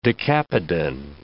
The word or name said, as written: decapodan